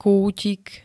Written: kútik

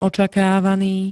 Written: očakávaný

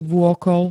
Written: vôkol

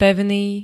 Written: pevný

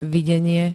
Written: videnie

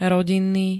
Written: rodinný

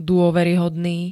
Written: dôveryhodný

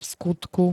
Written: vskutku

